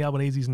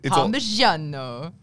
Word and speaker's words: Albanese: Yeah, so why is he pronouncing it like a white dad pronounces Parmesan cheese in a restaurant? Like it's Parmigiano.